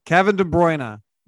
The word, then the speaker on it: Kevin De Bruyne: Kevin De Bruyne.